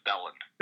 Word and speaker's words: Belan: Yeah, it's pronounced Belan.